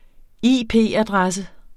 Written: IP-adresse

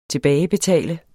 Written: tilbagebetale

